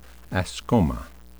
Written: ascoma

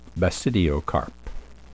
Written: basidiocarp

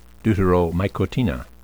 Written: deuteromycotina

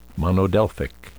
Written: monodelphic

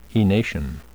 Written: enation

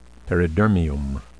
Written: peridermium

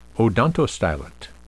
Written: odontostylet

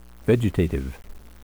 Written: vegetative

